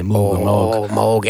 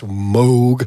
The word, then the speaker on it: Moog: Moog. – Yeah, correctly pronounced Moog.